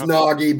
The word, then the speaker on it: Naugy: It's Naugy, but it doesn't matter to me.